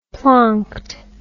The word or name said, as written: plonked